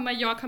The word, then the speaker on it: Mallorca: In Klammern dann nochmal Mallorca mit J geschrieben für die Aussprache.